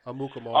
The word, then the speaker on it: Amukamara: Prince Akamuka. – Mukamura. – How you pronounce that name? – Amukamara.